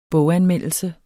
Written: boganmeldelse

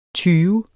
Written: tyve

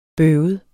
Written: bøvet

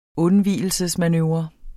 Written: undvigelsesmanøvre

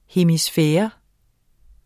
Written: hemisfære

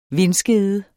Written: vindskede

